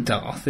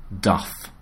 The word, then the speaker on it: Dubh: Now, it definitely isn't Darth, is it? – Not Darth Vader. – No, it's Dubber. – Well, yes, it's spelt, well, variant spellings, but D-U-B-H. – Dubber. – It's pronounced Duff. – As in Duff Beer.